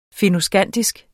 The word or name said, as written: fennoskandisk